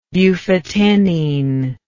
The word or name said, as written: bufotenine